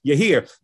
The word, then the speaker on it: Yireh: We say Yireh, but it's Yahir.